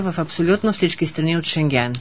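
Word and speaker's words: Schengen: Последната тастричка слагаме ударение. – Улаксаме. Наше въпа Шенген. – Шенген. Шенген. – Да, Шенген е верното.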